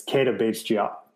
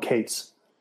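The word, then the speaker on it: Keita Bates-Diop: I didn't even pronounce it right. It's Kata Bates Jop. I called him Cates.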